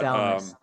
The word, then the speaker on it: Salamis: But, Salamis, okay. – And that's been kind of traditional in English for a long time. You can tell from where poems have it, the accent was on the first syllable, but don't worry about it.